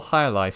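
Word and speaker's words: jai alai: It's a sport called Hyalai, spelled with a J.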